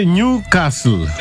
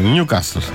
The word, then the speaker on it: Newcastle: Προφέρεται Νιου Κάστλ. – Δεν έχει τάφ, έχει δίκιο. – Να, έχει δίκιο, Νιου Κάστλ. Άνευ του.